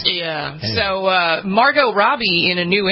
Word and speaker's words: Margot Robbie: That is still in theaters right now, and that stars, and we're going to say her name correctly for once, Margot Robbie. Not Margot.